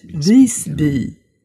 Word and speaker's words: Visby: Es wird im Deutschen oft Visby ausgesprochen. Ne? Visby, das klingt so ein bisschen wie so eine aggressive Biene. Es ist aber im Schwedischen Visby. Also Vis, dieses I wird erstmal relativ weit hinten in der Kehle gesprochen. Und da erstmal der Wort Visby. Der besteht aus zwei Teilen, einmal aus Vis und einmal aus Bi, also Bi heißt Dorf.